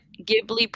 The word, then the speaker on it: Ghibli: Well, like, I just put Ghibli pronounce, and then it says studio, blah, blah, blah, blah, and then it gives you, like, how do you pronounce it, and it says Ghibli.